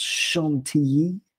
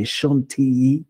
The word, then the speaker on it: Chantilly: It's actually pronounced Chantilly in French. You sort of pronounce the double L as a Y, Chantilly. Like Bastille doesn't exist in French. It's Bastille, same kind of thing, double L. Pronounce it like a Y.